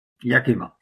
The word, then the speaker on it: Yakima: Das war die Firma Yakima, ist, glaube ich, richtig ausgesprochen, oder? – Yakima, ja.